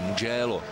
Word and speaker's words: Mugello: září, hned po něm tisící závod Ferrari, velká cena Toskánská na okruhu Mugello se to vyslovuje, italský Mugello.